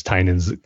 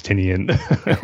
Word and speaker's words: Tynion: So I'm going to give this one four out of five uh, ways I mispronounce uh, James Tynion's correct name as James Tinian or otherwise.